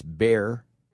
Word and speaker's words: Bexar: I have no idea why the X is silent, but it is, and it's Bexar County.